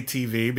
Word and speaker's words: TV: I love hearing Whitney say TV because he puts the emphasis on the T.